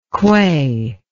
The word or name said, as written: quey